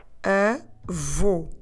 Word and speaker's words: avô: I want you to put your lips round and forward as if you're going to whistle in order to pronounce the sound u. Avô. In terms of spelling, this word takes this accent called circunflexo, or in English a circumflex, on the letter O. And that circumflex on the letter O, it's there to tell us that we need to close the vowel.